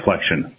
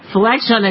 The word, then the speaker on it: Flexion: And just as a point of clarification, we pronounce the company Flexion. – As in bending at the knee or the elbow, so there's kind of a connection there. – Flexion, of course, that makes sense.